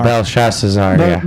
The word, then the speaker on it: Belshazzar: Belshazzar.